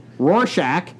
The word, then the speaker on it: Rorschach: Number five, from Chris Richardson, Rorschach.